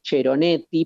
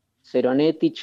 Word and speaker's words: Ceronetti: El texto se llama El Cantar de los Cantares y el autor se llama Guido Ceronetti. En realidad debería ser Ceronetti porque se escribe con C. Ceronetti.